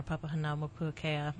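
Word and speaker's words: Papahānaumokuākea: and then most recently our Papahanaumokuakea Marine National Monument.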